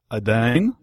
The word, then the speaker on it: Aidane: I've got a special guest, Samad Aidan.